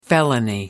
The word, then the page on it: felony: American English